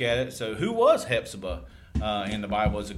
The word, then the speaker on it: Hephzibah: So you got the PH where it's a Hephzibah. – I was today years old, like just now realizing we don't say the P.